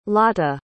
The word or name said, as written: lata